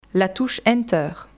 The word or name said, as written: la touche enter